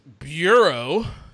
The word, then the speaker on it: Bureau: why, Burrow, Bureau. Like, do I gotta pronounce every little fucking shit? It helped. Keep going. It helped. – okay. and then what happened? – Better Business Bureau. Is that better? Yes. yes.